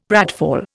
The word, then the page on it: pratfall: Indian